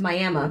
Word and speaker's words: Miami: It's pronounced Miami because that's the name of the tribe.